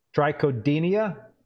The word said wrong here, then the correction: Trichodina: Is that correct? – Yeah. Trichodina.